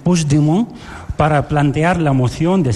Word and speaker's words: Puigdemont: Me has preguntado antes, ¿cómo se dice este nombre? – Sí, voy a intentar. – No, Puigdemont. – Puigdemont. Vale. – Puigdemont. – Eso es. – Sí, lo dices como francés, así.